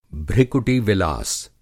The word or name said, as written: भृकुटि-विलास